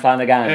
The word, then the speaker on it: Flanagan: That's how you pronounce that. Nathan Flanagan. – It is. That's how they pronounce it in Castle Milk, where he's from. Nathan Flanagan.